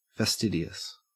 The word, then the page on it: fastidious: Australian English